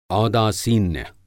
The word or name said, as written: औदासीन्य